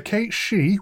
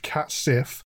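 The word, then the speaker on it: Cat Sìth: The Cate She, what looks like it should be pronounced Cat Sif, have the appearance of large black cats, but come with all the mischief and mayhem of the Fae.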